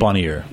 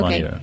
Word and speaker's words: Bonnier: No, it's Bonnier. – Okay. Bonnier.